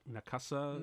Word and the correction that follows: Nausicaa: Nausicaa. Nausicaa of the Valley of the Wind.